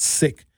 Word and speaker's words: Sikh: It's actually not pronounced Sikh, it's pronounced Sikh. Sikh, S-I-K-H, like that Indian culture.